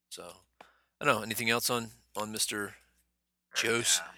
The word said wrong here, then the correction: Joss: How do you say his first name? Jose? – I think Jose? It's Josh. – No, it's not. It's J-O-S-S. – Joss. – That's it. Joss. How you say it.